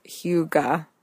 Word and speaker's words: hygge: It's spelled H-Y-G-G-E, and it's pronounced hygge.